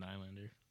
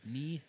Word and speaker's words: Nylander: We're going to go with Toronto forward William Nylander. – Nylander. – Nylander? – Nylander. – I'm so used to pronouncing it as Nylander instead of Nylander.